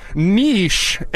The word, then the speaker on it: niche: How do you pronounce the word N-I-C-H-E, ? At Dom Show 1210. Still 34 minutes left, so you can make a comeback if you're anyone wants to help. Niche, N-E-E-S-H, 64% over niche. How you pronounce it, 36%, like a weird person.